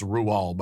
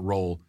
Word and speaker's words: Roald: Yes, it is pronounced Roald, by the way, not Roll.